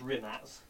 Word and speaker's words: Rimac: It's actually pronounced Rimac.